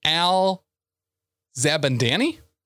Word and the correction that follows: Zabadani: Syria. – Zabadani. – Oh, that probably is actually how it's said. Syria, right? – It's Syria.